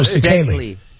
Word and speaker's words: Szekely: S-Z-E-K-E-L-Y. – Oh, Sekely. Nobody can say it.